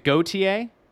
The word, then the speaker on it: Gauthier: Is it Gauthier? That's how it should be pronounced.